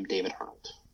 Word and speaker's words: David Arnold: and um, I'm on Instagram at I am David Arnold. – Okay, and and that's spelled standard D A V I D and then A R N O L D.